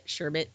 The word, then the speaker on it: sherbet: You feel like a frog when you're saying it. Sherbet. Like ribbit, sherbet.